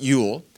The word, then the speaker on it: Yule: And that they spelled it J-O-L, but we pronounce it Yule.